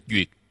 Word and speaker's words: duyệt: Cái chữ duyệt này trên thực tế nó là thuyết. Nó là chữ thuyết. Ở chỗ này phải đọc là duyệt.